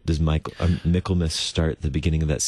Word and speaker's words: Michaelmas: Michaelmas. – But yes. – Michaelmas. – Yeah. Hmm. Yeah. That was the pronunciation I found online, was Michaelmas. But yeah, that's just like Christmas, right?